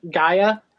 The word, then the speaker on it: Gaea: It's spelled G-A-E-A, Gaia.